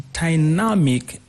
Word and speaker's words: dynamic: That's a very important question because the word dynamic and pragmatic, they work the same in terms of stress. So you're going to have to put the stress on the first syllable, on the second syllable, dynamic. The second syllable, dynamic, pragmatic.